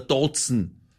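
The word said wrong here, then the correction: DAZN: Das eine heißt nicht Sky, sondern Sky und das andere spricht man der Sohn aus.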